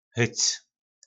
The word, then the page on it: hyc: po nasimu